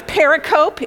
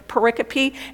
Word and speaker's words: pericope: It looks like it's pericope. If you look at it, it looks like it's pericope. It's pronounced pericope, and it means a chunk of Scripture.